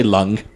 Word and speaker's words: Leung: Just say Leung. – Leung. – There you go.